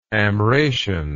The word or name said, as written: Amratian